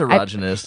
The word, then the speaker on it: erogenous: Is that how you pronounce that? I've literally never known. – It is erogenous.